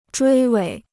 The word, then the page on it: 追尾: zhuī wěi